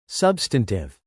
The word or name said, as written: substantive